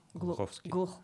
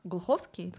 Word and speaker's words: Глуховский: хотя это был Глуховской, но... – Глуховский. – Глуховский, да. Извините, я почему-то путаю ударение, но диалоги настолько не соответствуют возрасту персонажей, то есть, ну, они молодые там.